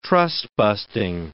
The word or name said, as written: trust-busting